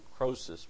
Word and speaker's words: Croesus: C-R-O-E-S-U-S. You might read it Croesus, but I think it's pronounced Croesus.